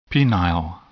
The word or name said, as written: penile